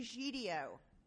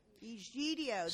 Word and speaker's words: Egidio: Egidio. – Yeah. – Egidio. – See, I see the G is pronounced G.